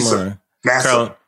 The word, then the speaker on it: Nassib: Carl Nassib, is that how you pronounce his name? – Nassib.